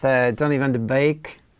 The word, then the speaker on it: Donny van de Beek: We're starting with Donny van de Beek. That's the correct pronunciation as reported by Phil yesterday.